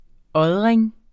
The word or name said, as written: ådring